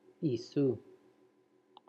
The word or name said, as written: Isou